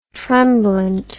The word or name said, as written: tremblant